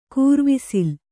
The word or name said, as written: ಕೂರ್ವಿಸಿಲ್